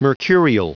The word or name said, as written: mercurial